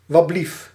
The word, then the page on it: anteeksi: [ˈɑnteːksi]